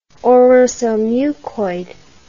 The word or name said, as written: orosomucoid